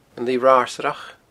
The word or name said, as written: Lighe Ratharsaireach